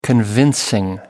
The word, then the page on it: convincing: American English